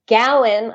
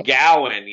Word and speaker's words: Gowan: How often does Go On perform the national anthem? Am I pronouncing his name right? – No, you're not. Gowan, I think, is who you mean to say. – Oh, Gowan, yes.